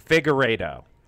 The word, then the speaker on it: Figueiredo: Figueiredo.